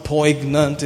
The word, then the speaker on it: poignant: The spelling of the word P-O-I-G-N-A-N-T, to be pronounced, is not poignant, it's poignant.